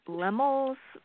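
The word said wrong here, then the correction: Laemmle: Royal Theater. I might be butchering the name. – Isn't that how you pronounce it, Stephanie? – I think they call it Lemley.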